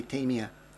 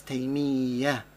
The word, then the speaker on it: Taymiyyah: In the English language, when we speak about him, subhanAllah, many times people say Taimiyah, Taimiyah, Taimiyah, because it just rolls off the tongue easy for English speaking people. However, his name is pronounced as Taimiyah, Taimiyah.